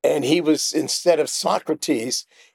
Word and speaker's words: Socrates: But I just wanted to share with you that the way that we pronounce that word is Socrates.